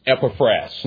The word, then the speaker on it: Epaphras: I was humbled when I went to the pronunciation guide. I should have known it. It is Epaphras.